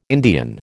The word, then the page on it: Indian: American English